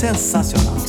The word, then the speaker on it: sensacional: Well, let me tell you, it's pronounced sensacional. Sensacional.